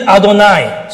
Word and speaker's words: Adonai: So when they actually read this word, they read it Adonai.